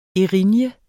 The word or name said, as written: Erinyerne